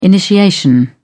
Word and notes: initiation: British English

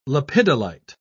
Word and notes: lepidolite: British English

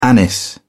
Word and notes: anise: British English

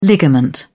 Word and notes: ligament: British English

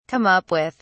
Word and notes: come up with: British English

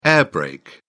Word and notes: air brake: British English

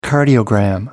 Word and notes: cardiogram: American English

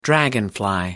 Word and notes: dragonfly: American English

